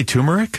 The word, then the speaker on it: turmeric: Is it really turmeric? – It is, yeah.